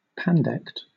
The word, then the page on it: pandect: British English